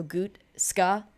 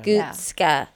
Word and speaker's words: Gutozke: G-U-T-O-Z-K-E. So it's German. So like if you pronounce if you think there's like an A at the end, it makes it a little better. So Gutzka. – Yeah. – Gutzka.